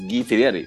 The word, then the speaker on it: Guy Fieri: I believe it's pronounced Guy Fieri. – Is it Guy? Oh shit! – I think it's Guy. Yeah.